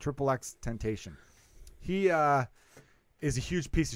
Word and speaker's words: XXXTentacion: And the joke was mentioned this rapper whose name I'm going to not pronounce correctly. – XXXTentacion. – XXXTentacion? – Yeah. – Okay, cool. I don't know why I was saying like XXXTentacion or something.